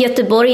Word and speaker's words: Göteborg: know that it's pronounced Göteborg in Swedish? It's not well, Gothenburg.